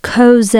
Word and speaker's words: chozeh: It's Strong's 2374, and it's Coze. It's spelled C-H-O-Z-E-H, and it means a beholder in a vision.